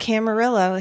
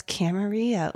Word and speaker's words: Camarillo: Not Camarillo, it's Camarillo.